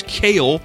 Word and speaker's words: Cale: It's Cale, kind of like the vegetable, but not the K. Yeah, it's spelled with a C. Actually, it's Caleb. You just take the B off of it, and it becomes the NASCAR champion back in the day when I was born and my dad was a big fan, right?